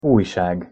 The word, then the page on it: ujsag: Hungarian